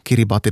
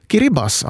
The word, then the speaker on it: Kiribati: Där T-I i slutet av då, Kiribati, då man läser det sådär ordagrant, uttalas som S. Kiribas alltså.